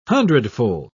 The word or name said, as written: hundredfold